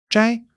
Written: 摘

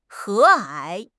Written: 和蔼

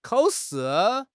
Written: kao3 shi4

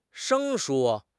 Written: 生疏

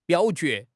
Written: 表决

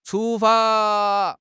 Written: chu1 fa1

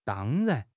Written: dang1 ran2

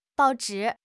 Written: bao4 zhi3

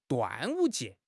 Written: 端午节

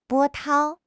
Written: 波涛

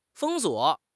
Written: 封锁

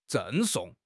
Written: 赠送

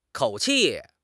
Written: 口气

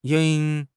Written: ying2

